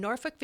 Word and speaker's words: Norfolk: I'm in Houston, Texas, and Polly is talking to us from Norfolk, Virginia, a city name that I always feel like I'm swearing when I say it. – No, you're saying it correctly. – That's how you know you're saying it correctly, is you feel like you're saying a bad word.